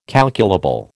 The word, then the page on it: calculable: American English